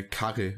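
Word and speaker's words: Kare: Ach, ah, ich habe übrigens herausgefunden, der heißt äh, Karre, also, also so wie die Japaner Curry aussprechen. K-A-R-E, r Karre.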